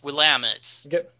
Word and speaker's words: Willamette: But no, at the end of the game, you go to Willamette, you know, Willamette County, and it's pronounced Willamette.